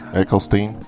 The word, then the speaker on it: Eccleston: Did I say that right? – Christopher Eccleston? – Eccleston? – Yep.